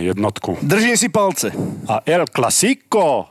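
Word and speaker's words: El Clásico: El Clasico. Dobre som to vyslovil? Áno.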